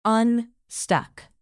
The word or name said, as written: unstuck